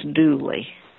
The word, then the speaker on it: Dula: So his name was spelled D-U-L-A, but it was pronounced Dooley.